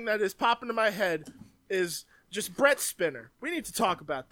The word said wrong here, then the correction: Spiner: Spiner. – So Spiner. – Spiner? That's how you say it? – Yeah.